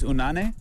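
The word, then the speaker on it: Unanue: Robert Unande? Am I pronouncing that right? – I think it's Unane.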